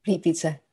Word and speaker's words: Plitvice: Plitvice.